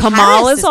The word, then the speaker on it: Kamala: And Kamala's all right.